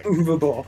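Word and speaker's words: Uwe Boll: Uvabowl. – Uvabowl. Is that how you pronounce it? I've always said U. – Yeah, it's... – I'm an idiot, I guess. – W is pronounced as V.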